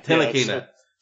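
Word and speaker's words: Ntilikina: I've liked what I've seen of Frank Nit, I don't know how to say his name, Nitalkina, I don't know. – Tilakina. – Silent N. Silent N, the French guy.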